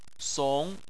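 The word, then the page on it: song2: tone 2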